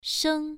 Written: sheng1

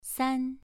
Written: san2

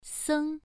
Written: seng1

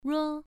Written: ruo1

